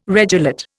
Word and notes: regelate: Indian

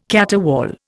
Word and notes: caterwaul: Indian

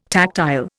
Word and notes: tactile: Indian

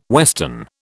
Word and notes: western: British English

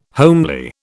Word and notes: homely: British English

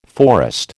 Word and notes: forest: American English

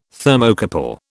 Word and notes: Thermocouple: British English

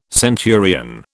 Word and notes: centurion: British English

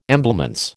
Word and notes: emblements: American English

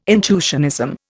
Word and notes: intuitionism: Indian

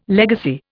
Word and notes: legacy: Indian

